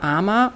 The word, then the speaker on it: Ama: Armer. Oft wird das A am Ende gar nicht ausgesprochen hier, dass man nur Arm sagt. Ja, es kommt auch vor. Aber wir kennen es unter AMA, A-M-A.